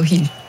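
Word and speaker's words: Gil: Je l'ai bien prononcé. Si. On dit Hill ou pas? – Si, Orlando Hill. Oui.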